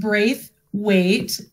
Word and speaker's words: Braithwaite: So it is pronounced Braithwaite, Lisa Braithwaite. If you haven't heard that before, it's because it's an English name. And when I go to England, everybody can pronounce it. It's fantastic, but not so much here.